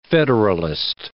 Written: federalist

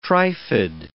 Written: trifid

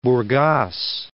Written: Burgas

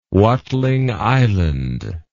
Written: watling island

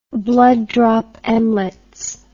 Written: blood drop emlets

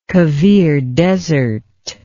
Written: Kavir Desert

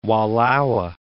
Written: Wallowa Mountains

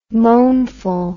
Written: moanful

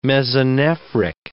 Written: mesonephric

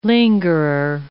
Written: lingerer

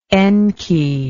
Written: enki

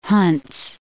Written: Hunts

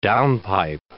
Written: downpipe

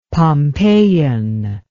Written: pompeian

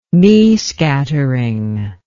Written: mie scattering

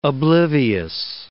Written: oblivious

